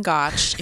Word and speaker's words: Gotch: So it's Jen Gotch. If you don't know who she is, it's at Jen Gotch. It's spelled exactly how it sounds on Instagram.